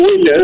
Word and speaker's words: Wilde: Bueno, está bien, pero usted sabe que ese es Oscar Wilde, pero ya le dije sí. Wilde o Wilde, depende sí. cómo usted lo quiera pronunciar.